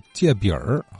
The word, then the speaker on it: 隔壁: 叫借饼儿啊。呃，隔字念成了借啊，这个这这这么一个音变，它怎么变过来的啊？